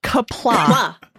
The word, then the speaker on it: Qapla': What is it? Kerplunk? – Kapla. – Kapla. Oh. – It's spelled Q-A-P-L-A apostrophe. Because you know, got to have the apostrophe.